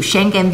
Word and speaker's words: Schengen: 他的意思就是说，你当然要跟台湾有所交往了。他这里就讲了两个例子，这两个例子，第一个就是说，像我们讲 Schengen Schengen Visa，它就是叫发音就叫 Schengen，是这样一个拼法。它的就是说呢，其实呃这次的 Czech Republic 它已经啊。在这个今年很早的时候，它是第一个停止对中国呃 issue Schengen